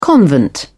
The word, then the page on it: convent: British English